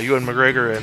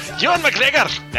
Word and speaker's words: Ewan McGregor: Kind of like that movie we were talking about last week where Ewan McGregor and Christian Bale were having the butt sex. Oh, yeah, I'm sorry, I pronounced his name wrong. Ewan McGregor! – That's better.